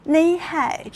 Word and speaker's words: 你好: 你好用苏州话怎么读？我现学现卖一下啊，叫内海，真的是吴侬软语啊，好好听啊。